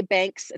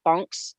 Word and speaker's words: Banchs: Sandra, I say Banks. It's not how you pronounce it. B-A-N-C-H-S, Bonks.